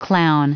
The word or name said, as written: clown